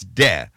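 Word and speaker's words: DeSantis: Whether to pronounce the first syllable of the governor's name as D or De. D or De. – Mm-hmm. – Actual newspaper headline here. Is it D or De? – Oh, God. – It's De. – Mm-hmm. – De. Ron De-fascist. That's the correct pronunciation.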